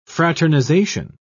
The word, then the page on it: fraternization: British English